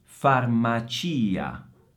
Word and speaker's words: farmacia: Questa non ha molti segreti, ma Occhio all'accento. Farmacia. Farmacia. Inghilterra.